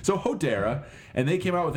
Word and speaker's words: Hodera: Hodera. It's like Hodor from Game of Thrones, just with an A at the end. Hodera. – Well, no, but with an E before the R.